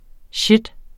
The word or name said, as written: shit